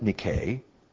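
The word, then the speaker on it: Nike (νίκη): Nike, or as the Greeks would pronounce it, Nike, means victor or conqueror.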